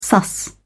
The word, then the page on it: suss: British English